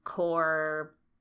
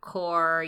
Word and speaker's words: Qor: and then Core. – So that's spelled Q-O-R, by the way. – Q-O-R, Core, Utrecht artist, Blick artist, what else?